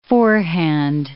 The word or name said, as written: forehand